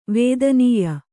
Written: ವೇದನೀಯ

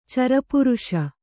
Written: ಚರಪುರುಷ

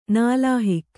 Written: ನಾಲಾಹಿಕ್

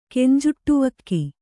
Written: ಕೆಂಜುಟ್ಟುವಕ್ಕಿ

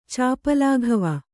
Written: ಚಾಪಲಾಘವ

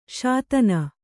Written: ಶೋಧನಿ

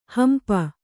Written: ಹಂಪಿ

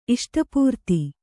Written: ಇಷ್ಟಾಪೂರ್ತ

